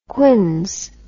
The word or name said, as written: quins